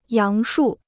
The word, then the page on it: 杨树: yáng shù